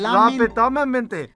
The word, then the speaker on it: lamentablemente: Lamentablemente. – Lamentable mante? – Mente, fool. – Okay. – Usa tu mente, güey. – Okay. – Lamenta. You got it. You almost got it. Lamentablemente. – Lamentablemente.